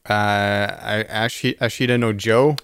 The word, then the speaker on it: Ashita no Joe: And then I watched all of Ashita no Joe.